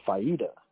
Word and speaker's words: faida: the faida it's it's spelled F A I D A, right?